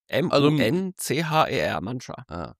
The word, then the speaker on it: Muncher: Munch. M-U-N-C-H-E-R, Mancha. Wie die Manchis, wenn man gekifft hat.